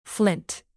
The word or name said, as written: flint